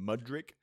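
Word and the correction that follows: Modrić: It reminds me of I just call him Luka Modric. That's what I just call him. – Modric. Okay, yeah, that works for me.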